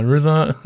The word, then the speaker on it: RZA: Und äh, R RZA. RZA. – RZA. Ja, RZA. Robert Fitzgerald Dix.